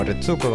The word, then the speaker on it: Retsuko: O Retsuko, como quieran decirle, la uno del medio se pronuncia Retsuko.